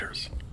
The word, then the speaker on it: Reuters: It's pronounced Reuters. – What do I say? – Reuters. – Oh. – Roto-Reuters. – Merlin, okay, wow. The people that were going to buy the stuff, the Reuters or something. I didn't get it. – Reuters is the news agency that you're referring to. Sorry. – Where I'm from, we pronounce it Reuters.